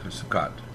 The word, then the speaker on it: Sukkot: And what is the right pronunciation for it? She's wondering. – S-U-K-K-O-T, Sukkot. – It's not Sukkot? – Well, you can do it other ways. There's people who say it that way, too, yeah.